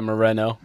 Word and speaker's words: Moreno: Do you pronounce it Moreno? – Yeah, Moreno.